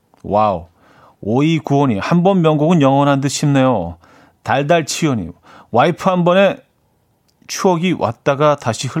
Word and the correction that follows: 와이퍼: 아, 와이퍼. 와이프 아니죠. 와이퍼죠.